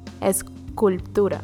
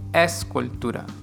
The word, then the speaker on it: escultura: Escultura or sculptura? – Escultura. – Escultura. Mm-hmm. Yeah, that throws me off every time. So sculpture is...